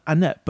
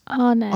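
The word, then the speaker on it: Annett: See, I always used to call her Annette, but it's Arnett. – Arnett. – Yes. – And that's how you would usually pronounce it if it had an R in it. So it's Chloe Arnett, not Chloe Annette.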